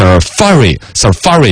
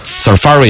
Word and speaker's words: Safari: 他还专门搜了一个这个英语字典那个出来，实际的发音呢是 Safari 应该是这样子读，嘎。 Safari 应该是重音在第二个，好像我之前是有印象的 Safari 浏览器，苹果的浏览器 Safari。